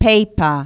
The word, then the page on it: paper: English